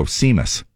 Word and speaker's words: Seamus: And so then today, now I'm going to spell out this guy's name, and I want you to tell me how you would pronounce it, okay? – Okay. – S-E-A-M-U-S. – Seamus. – Oh, son of it's a... It's an Irish name.